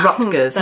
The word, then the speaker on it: Rutgers: Rutgers.